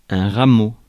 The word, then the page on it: rameau: France; [ʁa.mo]